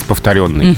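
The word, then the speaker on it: повторённый: А повторенное или повторенное, учитывая, что ежа, оно должно быть обычно под ударением, все дела. Как правильно? – Вот здесь а, есть варианты, но они неравноправны. – Угу. – Нам с вами в эфире перед микрофоном следует говорить повторенный, угу.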